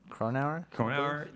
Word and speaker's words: Cronauer: Cronauer? – That's it, yeah.